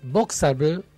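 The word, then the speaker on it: Boxabl: Es una unidad modular del fabricante Voxable.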